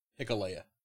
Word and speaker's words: Hikuleo: We um, get the IW, IWGP United States Championship match Lance Archer versus, and I'm going to butcher this, Hikulo? – Hikalea. – Hikalea? Yeah. Okay. H I K U L E O. Yeah. Hikalea.